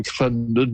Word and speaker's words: Llandudno: We're here in Clendid now. It's spelled Llandudno.